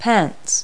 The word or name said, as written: pants